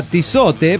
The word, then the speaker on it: Tissot: Póngale, sí, sí, bueno, Tissot se dice Tissot y no Tissot. En ese caso se escribiría Tissote para que se pronuncie la T.